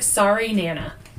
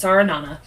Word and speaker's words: Sarinana: Okay, the way it's spelled is, looks like Marinara with an N at the end. – Oh. – So it's like, it looks like Sari Nana. – Oh. – S-A-R-I-N-A-N-A, but it's Saranana.